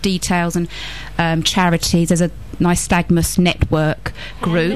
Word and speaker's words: nystagmus: And they said nystagmus, N-Y-S-T-A-G-M-U-S, nystagmus.